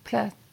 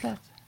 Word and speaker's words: Plath: hur, säger man Plath? Hur uttalar man hennes efternamn? Plath. Plath. Plath. Plath. Sylvia Plath.